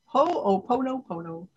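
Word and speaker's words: Ho-o-pono-pono (Hoʻoponopono): I might not be saying it right. Ho-o-pono-pono. – Right. Perfect.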